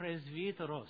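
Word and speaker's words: presbyteros (πρεσβύτερος): By the modern pronunciation in Greek, it's a presbyteros. Okay? You know, we're called Reformed Presbyterians. It's because it's from the same word, presbyteros, or presbyteros.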